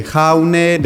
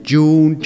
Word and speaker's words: Jaune: The problem, I pick up the wrong name because all the Spanish speaking say Jaune, they don't know how to pronounce it, and all the English speaking people say June, Jaune, June, they don't know. So it means yellow in French actually, jaune.